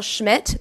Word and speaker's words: Schmidt: My name is Rachel Schmidt. That's spelled S-C-H-M-I-D-T.